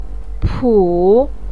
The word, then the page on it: pu: 3